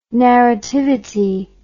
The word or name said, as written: narrativity